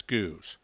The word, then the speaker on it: Gueuze: the barrel-aged and blended style of Belgian lambic known as Goose. That's spelled G-U-E-U-Z-E, and is the French name for the style.